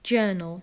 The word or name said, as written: journal